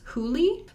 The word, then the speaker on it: Hole: So, well, I think that there is a different pronunciation of it. And I think we have lost a little bit of that during the translation. – Mm. – It may have been pronounced in other places as Hooli. Because apparently while he does some investigating in Australia, they call him Harry Holy, which is like kind of a combination of Hooli and Hole.